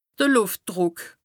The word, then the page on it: de Lùftdrùck: Bas-Rhin; Strasbourg